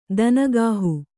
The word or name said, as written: ಧನಗಾಹಿ